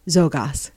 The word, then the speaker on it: Czolgosz: That's spelled Czolgosz. Very hard to pronounce. Czolgosz. – Was that Ukrainian? – It's a Polish name.